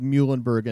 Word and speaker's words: Muhlenberg: I think if you know that the team's name is the Mules, then you ought to be able to understand that they're called Muhlenberg and not Muhlenberg.